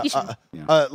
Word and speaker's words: Ishin: I'm thinking Like a Dragon Ishin. – I like that as well. – Am I saying that right? – Yeah, Ishin.